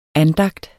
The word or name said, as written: ANDAGTENS